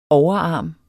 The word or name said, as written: overarm